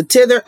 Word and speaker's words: Tethr: And it's spelled T-E-T-H-R. So it's a Tither app, and it is for men.